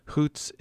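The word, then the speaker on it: Xóots: Hoots is pronounced with a raspy H sound and written in Tlingit orthography with the letter X.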